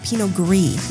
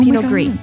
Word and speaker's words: Pinot Gris: So this wine fun fact, Billy, it's Pinot Gris. You do not pronounce the S. It's French. – Oh, Pinot Gris.